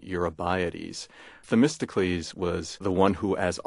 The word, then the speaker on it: Themistocles: There are a number of interesting characters whom you portray quite vividly in this book, starting with, and you'll have to forgive my pronunciation and hopefully correct me, Themistocles. Is that close? – Let's make it Themistocles.